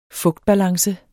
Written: fugtbalance

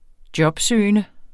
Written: jobsøgende

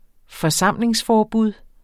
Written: forsamlingsforbud

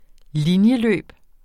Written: linjeløb

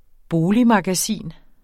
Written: boligmagasin

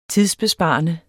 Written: tidsbesparende

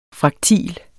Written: FRAKTIL

